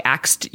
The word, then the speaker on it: ask: I asked you a question, like A-X-E-D. And the number of people that mispronounce the word ask by saying axe, like they swap the S and the K around, it honestly just blows my mind.